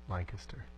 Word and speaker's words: Lancaster: It's pronounced Lancaster. – Lancaster. Lancaster. Lancaster. Sorry.